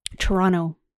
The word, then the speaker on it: Toronto: That, see Toronto. How she said it? That's how you say it. – Toronto. Yeah. Not Toronto. Toronto. You really gotta chuff the beginning too, if you want to sound like a Toronto.